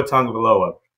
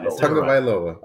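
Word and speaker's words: Tagovailoa: yeah, I did say it right. So look, I said it right. Tungvaloa.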